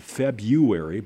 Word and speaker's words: February: Coming up in February, I've always said February, but it's February. – I think the R is silent.